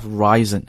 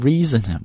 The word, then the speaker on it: risen: So that's why its shares have risen. 啊，他们的这个股票的价格也在往上走。注意，这个 risen, 很多同学会把读成 reason, 不对啊，这个词千万不能按字母读，它就是 rise 的过去分词，所以读 risen, have risen。